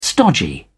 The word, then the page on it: stodgy: British English